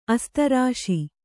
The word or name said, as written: ಆಸ್ತರಿಸು